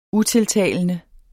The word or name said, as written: utiltalende